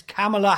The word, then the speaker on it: Kamala: And his lovely VP, who can everyone please start pronouncing her name properly? It's Kamala Harris.